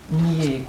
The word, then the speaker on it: nier: Nier compte pour deux syllabes. – Et nier. – Phonétiquement, dans la conversation, une seule syllabe.